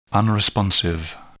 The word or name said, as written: unresponsive